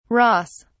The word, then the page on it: ross: British English